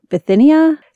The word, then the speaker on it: Bithynia: There was a reference by Pliny the Younger, who was the Roman governor of the province of Bithynia. Is that how you say it? Sounds good.